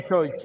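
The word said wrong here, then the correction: Ćorić: Coric. Ah. las dos veces. Muy forno. Borna y Coric.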